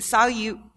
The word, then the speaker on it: Salyut: It's called the, is it pronounced Salyut? – Salyut, I think. – Salyut. Well, it's the Russian word for salute.